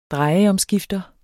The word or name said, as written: drejeomskifter